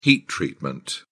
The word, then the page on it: heat treatment: British English